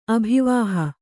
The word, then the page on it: ಅಭಿವಾಹಿ: abhivāhi